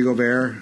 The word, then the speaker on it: Gobert: Defensive Player of the Year was Rudy Gobert. Is that how you say it? I think Utah. – Rudy Gobert.